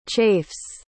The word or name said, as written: chafes